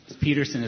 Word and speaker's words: Peterson: Peterson is spelled the right way, P-E-T-E-R-S-O-N.